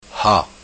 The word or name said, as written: Ha